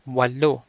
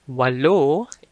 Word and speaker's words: walo: That's spelled P-I-T-O. Pito. Pito. Next is walo. Walo. Walo is 8. Number 8. Walo. So it's spelled as W-A-L-O. Walo. Walo.